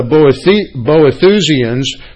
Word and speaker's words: Boethusians: Boethusians. There's a new word for you this morning. That's spelled B-O-E-T-H-U-S-I-A-N-S, Boethusians.